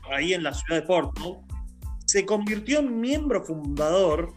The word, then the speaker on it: Portsmouth: Portsmouth Mientras comenzaba a escribir las novelas que lo harían famoso y tratar de vivir como médico, retomó su pasión por el deporte.